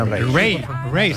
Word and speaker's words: Raye: Ray, se escribe Raye.